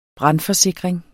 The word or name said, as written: brandforsikring